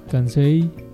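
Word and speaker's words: Cansei: ¿Cómo se pronuncia? – Así como lo dijiste. – A ver, pero pronúncialo. – Kansai.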